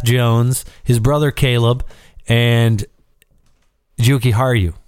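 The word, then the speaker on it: Jujhar Khaira: Juki Haru.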